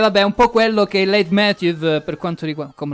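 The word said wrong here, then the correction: leitmotiv: no, non si dice così. Late Motive.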